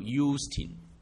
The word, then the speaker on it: Justin: 对了，Justin 有个名，英文名叫 Justin，应该读 Justin。